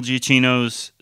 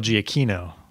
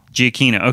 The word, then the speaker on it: Giacchino: Wait, it's not pronounced Giacchino. – Yeah, we were wrong. Jonathan Pauly, he corrected us. – Yeah, how is it pronounced? – Michael Giacchino. – Giacchino, okay.